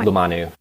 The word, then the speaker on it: Lumanu: Lumanu, right? Lumanu. – How am I? Lumanu.